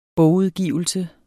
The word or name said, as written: bogudgivelse